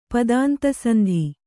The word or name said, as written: ಪದಾಂತಸಂಧಿ